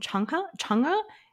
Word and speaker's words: Chungha: So, I've actually, we've had this song on repeat for like the past month, which is a K-pop song by artist named Changha, is I think how you pronounce her name, and it's spelled Chungha.